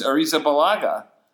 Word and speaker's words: Arrizabalaga: It's Arrizabalaga.